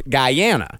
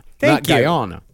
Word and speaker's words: Guyana: It's actually pronounced Guyana, Guyana.